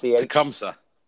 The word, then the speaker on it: Tecumseh: Tecumseh.